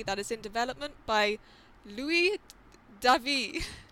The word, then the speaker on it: Luis David: Is that how you say it, though? Is it like Louis David? – Louis David. Okay. – Okay, so I'll just... – Louis David. – Yeah, that's how I would say it.